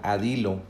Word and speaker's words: Adilo: Puedes entrar a elac.ee .ee e diagonal adilo, así como se escucha. Se escribe A D I L O.